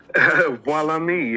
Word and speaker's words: Voilame: Walame, yeah. Walame. Just like the magic.